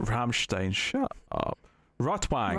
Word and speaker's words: Rotwang: It's probably a V sound. Yeah, you're right. Rotwang. Yeah. – Wang. Doesn't sound German. – Sounds like red dick. – Rotwang.